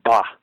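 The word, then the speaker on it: ba (霸): It's a word in Chinese pronounced ba, just like "ba" humbug.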